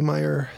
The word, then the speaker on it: Meier: They didn't show what year the Billy Meyer story was. And it's spelled M-E-I-E-R for anyone who wants to look it up.